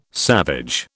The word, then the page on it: savage: British English